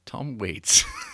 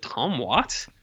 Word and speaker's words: Tom Waits: Tom Waits. – Well, I know it's spelt Waits, but for some reason I was like, is it Tom Watts? – Oh my God.